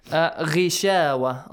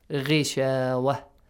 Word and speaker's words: غشاوة: Ghishawa. Ghain is a heavy letter, but because it has a kasra sound, so light. – It has to be lightly pronounced. Ghishawa.